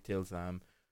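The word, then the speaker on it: tilsam (طلسم): And otherwise, the word talisman comes from the Arabic tilsan or tilsam. My Arabic pronunciation is normally better. The word talisman comes from the Arabic tilsam,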